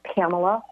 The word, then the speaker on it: Pamela: And my name is spelled with two L's, Pamela Horton, and that's Horton as in Horton, here's a who.